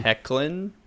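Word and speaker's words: Hoechlin: how do you pronounce his last name? – Hecklin.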